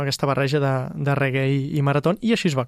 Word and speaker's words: reggae: Òbviament, el nom del reggaeton doncs, ve del nom reggae, però es pronuncia, això és molt curiós, no es pronuncia reggae o reggae, com li diu la gent, es diu reggae. – Reggae. – Aquesta és la pronunciació correcta d'aquest estil musical.